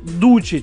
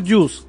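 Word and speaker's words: Duce: Ник, кстати, Дуче читается. Дуче.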